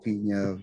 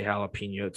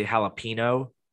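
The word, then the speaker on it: jalapeño: Thank God you say jalapeno, Tobes, because I've met a couple people in the last couple of years that don't pronounce it correctly. They say jalapeno, and it drives me. You're not drinking a Mexican wine. It's jalapeno.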